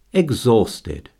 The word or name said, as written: exhausted